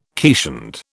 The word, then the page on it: keeshond: British English